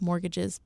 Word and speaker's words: mortgages: And that's mortgages, plural. And mortgages has a silent T in it, just in case you didn't know that.